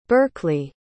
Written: berkleigh

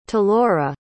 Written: talora

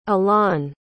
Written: allaun

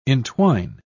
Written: entwine

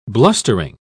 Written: blustering

